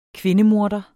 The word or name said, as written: kvindemorder